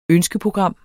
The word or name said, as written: ønskeprogram